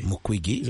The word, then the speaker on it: Mukwege: Où, pour rencontrer d'abord beaucoup d'artistes, mais aussi voir ou interviewer monsieur le docteur Mukwege. – Mukwege. – J'espère que je prononce bien le nom. – Trop bien, hein? Trop bien.